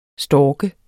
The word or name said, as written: stork